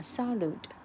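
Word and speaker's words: salud: Uh salute. Salute. Yeah, sorry. Salute so it's quite uh, similar to the pronunciation of, uh, it's quite similar to salute in pronunciation in English.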